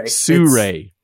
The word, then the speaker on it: Sirui: And that's when I went with, I pronounced it Siru, but I think you're right. Isn't it pronounced something another way? – Yeah, it's Suray. – Suray.